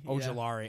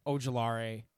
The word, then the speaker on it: Ojulari: Like Azeez Olujuwara. I think I just said his name wrong. – Ojulari. Yeah. – I'm sorry. It's late. You're good. – Ojulari.